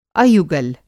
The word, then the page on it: अयुगल: Hindi